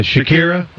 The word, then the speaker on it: Shakira: Yeah, Shakira.